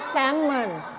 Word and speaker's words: salmon: ภาษาไทยเราจะเรียกว่า Salmon แต่ภาษาอังกฤษอ่านว่า Salmon Salmon ไม่ออกเสียง L นะ